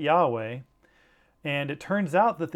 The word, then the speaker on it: Yahweh: The first is Lord in all capitals, L-O-R-D, all caps, which is the key for us to know that the Hebrew word Yahweh or Yehovah, those are the two common pronunciations, or Jehovah is another anglicized version of that pronunciation.